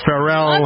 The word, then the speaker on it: Pharrell: Missy Elliott is going to mentor for Pharrell. – Love her. – I know you heard about it. You don't like it when I pronounce it. No, it's Pharrell. Yeah, it is Pharrell.